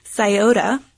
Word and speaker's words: Scioto: We won't relive the wrongness, but the correct pronunciation is Scioto. Or maybe even Scioto.